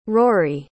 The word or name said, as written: rhory